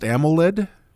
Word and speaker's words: AMOLED: Is it, how's that pronounced? AMOLED? The new screen type? – Yep. You hit it.